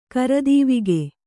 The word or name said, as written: ಕರದೀವಿಗೆ